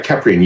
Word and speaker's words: Keprian: Yeah, Keprian, you've got it correct. And a lot of people pronounce it wrong. And Egyptologists have even said that I might be pronouncing it wrong. So it's all good. We'll go with Keprian, and that's going to be our common pronunciation tonight.